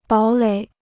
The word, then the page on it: 堡垒: bǎo lěi